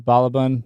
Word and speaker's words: Balaban: Balaban.